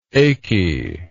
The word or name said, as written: achiness